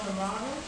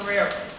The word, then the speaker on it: Guerrero: I hope I'm saying his name correctly, Antonio Guerrero. Guerrero, thank you. No, I wasn't saying it right. Antonio Guerrero.